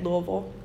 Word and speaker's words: Louisville: That's how you say it, Louisville, where a black grandpa was murdered.